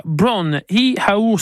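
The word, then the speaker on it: Bron-Yr-Aur: Avevamo ascoltato Led Zeppelin in 1970 con una canzone si chiama, aspettate, la pronuncia Bron i Haur Stomp, allora che è di galese che vuol dire culletta oro, è anche più bello un corso, culletta oro.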